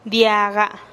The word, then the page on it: diaga: TdVZ